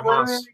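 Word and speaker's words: Maass: Moss, I think. – He's yeah, a Moss. Moss would be right, yeah.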